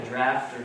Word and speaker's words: draught: and that is the English spelling of the word draft, and it is pronounced either draft or draught.